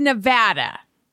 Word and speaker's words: Nevada: It's Nevada. Like, sad.